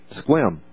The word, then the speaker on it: Sequim: I flew in to Seattle yesterday, got in late afternoon, had to fight traffic going all the way around to a place called Squim. It's spelled Sequim, right? Squim.